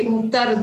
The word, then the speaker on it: Mukta: My name is actually Mukta Rudravajula.